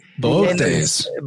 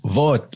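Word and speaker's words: Boötes: How do you say that? Boots? – Boates. – Boates. – The Greek way would be Boates.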